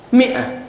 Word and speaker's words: مئة: زيادة الواو وزيادة الألف، نعم صحيح، زيادة الألف التي في مئة والتي لا تنطق، نعم أحسنت تجاوزناها. لاحظوا معي يا إخوان، زيادة الألف، زيادة الألف أين الزيادة في طبعا كيف ننطقها؟ مئة، واضح أن الألف ماذا؟ زائدة، ومثلها 300، إذن الألف زائدة.